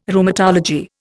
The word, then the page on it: Rheumatology: Indian